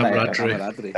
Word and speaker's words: camaraderie: How do you say that word again? – Camaraderie. – Thank you very much. I stumbled on that one last time.